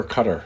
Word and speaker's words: Qatar: Is it Qatar or Qatar? – I don't know how to pronounce it.